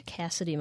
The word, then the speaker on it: Cassidee: I am on Twitter at Cassidy Moser. My name is spelled terribly. It's my mother's fault. It's C-A-S-S-I-D-E-E, and then Moser is M-O-S-E-R.